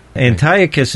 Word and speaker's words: Antiochus: Antiochus is the way I had always been taught to pronounce it.